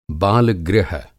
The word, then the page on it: बाल-गृह: Hindi